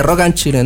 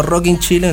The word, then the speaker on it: Rock and Chile: Y se dice Rock and Chile, no Rock in Chile, no Rock in Chile, no Rock and Chile, como Rock and Roll.